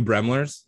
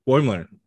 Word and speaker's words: Boimler: I want a face-off between William and the two Bremlers. Am I saying the name right? – Boilers. – What? Boimler.